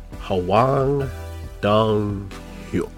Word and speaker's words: Hwang Dong-hyuk: Squid Game Season 2 announced from writer and director, I'm going to butcher his name, Hwang Dong-hyuk.